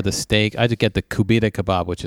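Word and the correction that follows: koobideh: is it's actually, I think they pronounce it Kubita. But that's the, Kubide.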